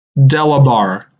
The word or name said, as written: Delabar